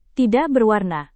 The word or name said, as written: tidak berwarna